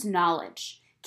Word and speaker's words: knowledge: K-A-T-E-L-Y-N is the correct way to spell it. So, since it is a K, we have knowledge, which sounds like an N, but really, it also starts with a K.